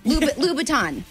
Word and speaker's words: Louboutin: Oh, it's Louboutin. You call them Louis Vuitton shoes. – Louis Vuitton. – Louis Vuitton. That's right. – Louboutin? – Louboutin.